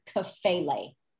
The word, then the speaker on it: kephale: So when Paul uses the word head, H-E-A-D, it is the Greek word kephale. It's spelled different ways, but K-E-P-H-A-L-E. Let's just go with that. K-E-P-H-A-L-E.